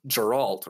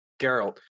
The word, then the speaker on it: Geralt: It's Geralt, right? That's how you pronounce it. – Geralt.